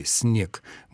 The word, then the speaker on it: снег: В современной речи «снег» мы совершенно спокойно произносим с твердым «с», а именно потому, что «н» принадлежит группе так называемых сонорных согласных, которые не обладают способностью так сильно влиять на предшествующий согласный «с».